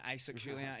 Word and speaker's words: Isaac Julien: Don't know if I'm pronouncing that right. Isaac Julien. – Doing a good job.